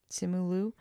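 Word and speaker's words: Simu Liu: Simulu? Is that how you say his name? – Simulu.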